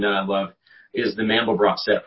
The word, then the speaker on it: Mandelbrot: For those of you who can't see the screen, that's spelled M-A-N-D-E-L-B-R-O-T. It's like George Frederick Handel, except it's Mandel and then B-R-O-T at the end, the Mandelbrot set.